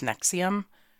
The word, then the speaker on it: NXIVM: But it's pronounced Nexium, um, not to be confused with the Nexxus hair product.